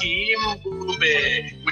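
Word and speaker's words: Mbube: The song was originally a hit in South Africa in 1939 for its rival Solomon Linda under its original title Mubumbi, pronounced Imbubi, which means lion.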